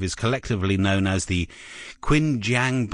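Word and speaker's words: Qingjiang: Quinn, you're going to say it like it's racy. Let's try it again. – The Qinjiang Biota. – Yes. – Like that.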